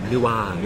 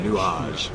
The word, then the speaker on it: Nuage: You have to say it, Nuage. – Nuage. My mother always tells me I'm saying it wrong.